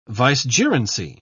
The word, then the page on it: vicegerency: British English